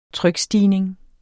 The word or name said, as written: trykstigning